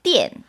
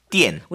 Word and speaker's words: 电: 그게 발음이 다시 한번 띠엔. – 띠엔.